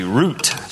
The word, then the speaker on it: Ruth: Her name in Hebrew is pronounced with a hard T, root, just like the root of a tree.